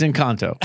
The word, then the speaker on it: Encanto: I think it's Encanto. Did I pronounce it right? No, it's Encanto.